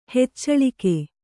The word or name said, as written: ಹೆಚ್ಚಳಿಕೆ